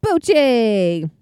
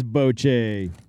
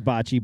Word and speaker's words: bocce: It's Boche. – What is Boche? – It's a game sometimes pronounced bocce ball. – Balls. – But it's really pronounced Boche.